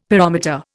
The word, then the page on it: pyrometer: Indian